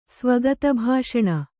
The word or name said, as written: ಸ್ವಗತಭಾಷಣ